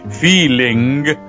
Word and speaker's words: feeling: que aunque es una palabra inglesa que se escribe totalmente diferente y se dice feeling,